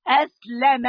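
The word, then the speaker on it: أسلم: يعني هذا القلب الذي اسلم، اسمعي اسمعي بقى بتاعت اللغه العربيه، خدي بالك من الكلمه اسلم، اللام مفتوحه، خدي بالك من النطق بس، الذي اسلم وسلم مكسوره وسلم مشدوده في الشد على اللام، وسالم واستسلم ادي واحد اثنين ثلاثة اربعة خمسة، خمس مواطن للعبودية.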